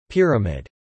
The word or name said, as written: pyramid